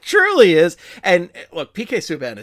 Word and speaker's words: Subban: The Kid Clancy Award for Best Humanitarian went to PK Subban, who, again, somebody who, much like Nazem Kadri, I have trouble pronouncing because it's always Subban, and I always say Subban because that's just how my brain thinks it should be pronounced. And it's like, no, it's Subban. It's like, okay, I gotta think Canadian with this.